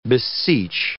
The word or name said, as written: beseech